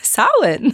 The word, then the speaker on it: Samhain: My teacher told me that. Samhain. Because M-H yeah. is a W.